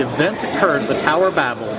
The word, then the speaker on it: Babel: And it's Babel. They actually stress the second part. It's almost identical to the way the French say it.